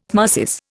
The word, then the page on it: tmesis: Indian